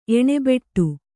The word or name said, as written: ಎಣ್ಣೆಬಟ್ಟೆ